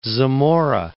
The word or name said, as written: Zamora